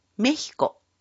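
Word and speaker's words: México: メキシコはスペイン語でメヒコと発音します。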